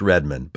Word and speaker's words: Redman: He was Native American, and his last name was Redman. Well, pronounced Redmond, but he was able to joke about it.